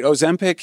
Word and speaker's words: Ozempic: Everybody's taking Ozempic. Am I pronouncing it right? – Yeah, that's right. Ozempic is essentially prescribed for diabetes, but it's often used for weight loss off-label, and doctors are prescribing it that way.